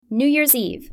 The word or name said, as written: new years eve